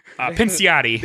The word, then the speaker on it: Pinciotti: Pinciotti.